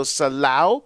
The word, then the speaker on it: Selah: You see a line, the transliteration is S-E-L-A-H, but it's pronounced, the A-H is actually Au in Hebrew, so Salau, which means rise up, exalt, right?